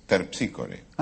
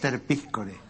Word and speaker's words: Terpsícore: Dije, es Terpsícore. – Ah, claro. Es Terpíscore. Es Terpíscore. Esa es la pronunciación griega, claro.